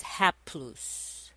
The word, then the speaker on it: haplous: That Greek word is haplous. And that's spelled H-A-P-L-O-U-S.